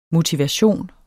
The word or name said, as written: motivation